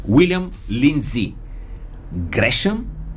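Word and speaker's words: William Lindsay Gresham: Уилям Линдзи Грешъм е така официалното произношение.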